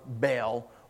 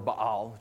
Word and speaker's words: Baal: What's happening here is he won't speak the names of Baal, or Baal, depending on how you pronounce that, or Moloch, because the name Baal literally means master.